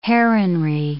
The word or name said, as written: heronry